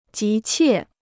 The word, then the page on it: 急切: jí qiè